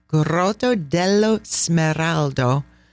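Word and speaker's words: Grotta dello Smeraldo: The emerald grotto, which in Italian is pronounced Grotto dello Smeraldo.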